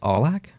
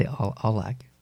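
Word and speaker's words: Aulac: It's A-U-L-A-C. There's a U in it, and I prefer to pronounce the U because... – Do you say Australia? – No. That's different. Because Olac comes from the French, Olac, at the lake. – Okay. – So it's O-A-U.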